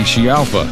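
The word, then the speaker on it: Scialfa: It's the 28-year-old backup singer, Patty Chialfa.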